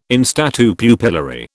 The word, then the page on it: in statu pupillari: British English